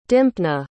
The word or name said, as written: dympna